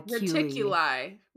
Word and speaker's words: Reticuli: How do you spell it? – R-E-T-I-C-U-L-I. Reticuli? Reticuli. – Reticuli. Reticuli? – Yeah, that sounds right.